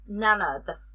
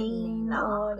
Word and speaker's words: Nena: So, Nana, the main character, her name is spelled Nena. So I would have pronounced it Nina in my head or whatever.